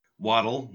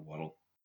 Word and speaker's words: Waddle: Waddle, like a penguin or a duck, you know, waddle.